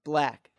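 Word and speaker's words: 6lack: Yes, it is spelled like that, but it's pronounced Black.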